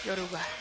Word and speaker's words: Yoruba: Of course, in Cuba, they say Yoruba. – Yoruba. – Yeah, they don't pronounce it Yoruba, but in Africa, it's pronounced Yoruba. – Right. – Yeah, if you say Yoruba in Cuba, they'll correct you and say, oh, Yoruba.